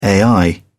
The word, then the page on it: ai: British English